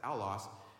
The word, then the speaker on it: allos: And it's a powerful word because another is just not another, but it is this Greek word, allos, or allos, that's how you pronounce it, allos.